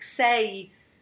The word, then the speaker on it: sei: Well, in some ways, like the Italian, like sei, that you have to hear both syllables of it.